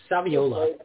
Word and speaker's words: Saviola: And then I'm not sure how these birthdays work out, but we're going to start off with Camellia Savolo. Hard to pronounce that. – I think it's Saviola. Yeah, Camille Saviola.